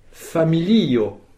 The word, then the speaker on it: familio: Très bon, et vous avez mis l'accent tonique sur le dernier i. Sur de la, donc, familio. Dans lio, il y a deux syllabes, et c'est bien le i qu'il fallait accentuer.